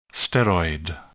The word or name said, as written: steroid